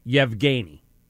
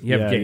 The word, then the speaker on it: Yevgeny: They pronounce it with a Y in front of it. Yevgeny. – Oh, it's Yevgeny. – Yevgeny. Yeah, that's, Yevgeny.